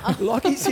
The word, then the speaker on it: Lachy: Lachy's here. So everybody got to say, wake up, Lachy. Now, Lachy rhymes with Rocky.